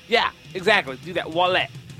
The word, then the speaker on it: Ouellet: Ouellet. – Ouellet. – Remember, you got to French it out, dude. It's Ouellet.